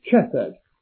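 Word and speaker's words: chesed (חסד): There is another word that's of great interest that's used in the Old Testament a lot, more than 250 times, which in English letters would be pronounced chesed, C-H-E-S-E-D.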